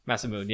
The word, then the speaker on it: Masamune: I'm going to pronounce it wrong. It's like Masamune or something. – You got it.